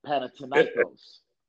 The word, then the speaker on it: Panathinaikos: And I've been saying it wrong for 20 years. What's the name? – Panathinaikos.